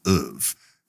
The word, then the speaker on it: oeuvre: I know that the correct pronunciation is oeuvre.